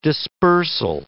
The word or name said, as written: dispersal